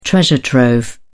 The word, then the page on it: treasure trove: British English